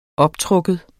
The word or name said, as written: optrukken